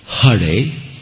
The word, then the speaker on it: Hare: Well, in the Sanskrit mantra, the correct pronunciation is Hare with the ha and Rama.